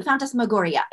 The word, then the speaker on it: phantasmagoria: Absolutely, phantasmagoria.